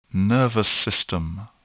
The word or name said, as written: nervous system